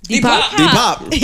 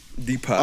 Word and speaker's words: Depop: Why'd you say it like that? – Depop. That's how you say it, right? Depop. – Depop. All this emphasis on the pop.